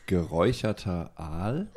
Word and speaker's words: geräucherter Aal: I probably said that completely wrong. It's like. – I know what you mean. – It said it's the most weird German delicacy food. Yes, how do you say it? – It's geräucherter Aal.